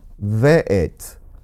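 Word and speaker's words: ve'et (ואת): Again, it says, Breshit bara Elohim Et, there again, Aleph Tav, Hashemayim ve'et ha'aretz. So the second time the word Et or Aleph Tav appears, notice that it's pronounced ve'et, and that's spelled Vav Aleph Tav.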